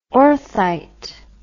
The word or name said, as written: orthite